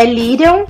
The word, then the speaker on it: Lyrian: É Lirion, como se pronuncia, e quem escolheu foi meu pai.